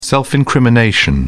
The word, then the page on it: self-incrimination: British English